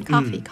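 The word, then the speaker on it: coffee: コーヒーではなくてカーって言うんですよね、カーフィー、うん、カーフィーって言うんですよね、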